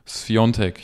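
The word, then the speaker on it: Świątek: Die Sviatek geschrieben wird, aber Sviontek, äh, Sviontek ausgesprochen wird, wie der ex-härter Fußballer äh, Piontek, der auch Piatek geschrieben wird.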